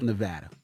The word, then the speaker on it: Nevada: People always say that's amazing, but how does that help Nevada? Did I say it right? – Mm-hmm. Yeah, how does it help Nevada? – Yeah.